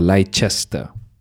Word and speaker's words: Leicester: L-E-I, C-E-S-T-E-R. Most Malaysians would say Leicester or Leicester, okay? But apparently the right way to say it is Leicester, because English is a freaking weird language.